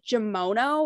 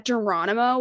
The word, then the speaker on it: Geronimo: A kid in class told me it's pronounced Geronimo, and I was like, it's not Germono, and he was like, no, and I was like, fuck you, I'm literally nine.